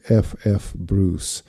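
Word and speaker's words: F. F. Bruce: По-английски его имя произносят обычно как F.F. Брюс.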